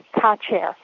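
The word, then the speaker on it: Kačer: And in Czechoslovakia, Kaser is pronounced Kacher. There's a little accent over the letter C to indicate that it needs to be pronounced Ch.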